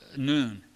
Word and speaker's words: nun: And the little dot just to the left of that's the vowel point that tells you how to pronounce that as noon.